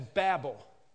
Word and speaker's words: Babel: I'm going to pronounce Babel as Babel. It's easier for me to remember it that way. In many of the commentaries, it has the long E slash over Babel.